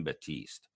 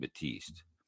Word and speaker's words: Batiste: Like there was an infielder for the Phillies named Kim Batiste, but he didn't call him Kim Batiste. He called him Kim Batiste. Like he added an extra syllable.